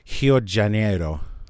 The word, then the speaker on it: Rio de Janeiro: Rio de Janeiro is how they say it. In Portuguese, R at the beginning of the word sounds like H.